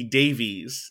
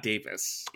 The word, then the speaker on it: Davies: It's pronounced Russell T. Davies, not Davis.